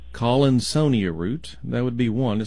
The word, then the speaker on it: Collinsonia: It's like the word Colin, and then almost like Sonia. – Well, that would make sense. – That doesn't even make sense the way I said it. Yes, I'm so brilliant in my phonic pronunciations. So it's called Collinsonia root.